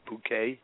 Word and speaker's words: Bucket: Her name was Bucket, and she pronounced it Bouquet.